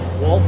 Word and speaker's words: Wulf: I'm Reverend Frank Wolf, and the last name is spelled W-U-L-F as in Frank.